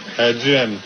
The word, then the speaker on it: Duhaime: Jim. – How do you spell that? – D-U-H-A-I-M-E. – D-U-H-I-M-E.